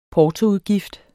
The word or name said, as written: portoudgift